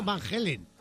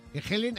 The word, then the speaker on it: Halen: De Van Halen. – Van Halen. Sí. – Van Halen. – Claro, Van Halen de toda la vida. – No, Van Halen. Helen, aquí no, no Helen.